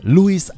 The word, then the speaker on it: Louis: Er wird englisch ausgesprochen mit S hinten aussprechen. Ne? Louis Armstrong.